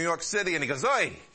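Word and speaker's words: Oy (הוֹי): That word ah is actually in the Hebrew. But it's pronounced more like this. Oy.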